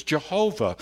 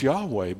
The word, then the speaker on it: Yahweh: They didn't even know what vowels belonged in there, and they mistakenly pronounced the word for centuries as Jehovah. Now we know the word is Yahweh,